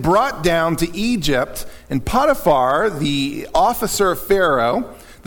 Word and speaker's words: Potiphar: And by the way, a lot of people pronounce it Potiphar. It seems like the more correct is Potiphar. And I'm probably going to, I grew up saying Potiphar, so you're probably going to hear me mess it up sometimes.